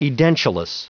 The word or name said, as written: edentulous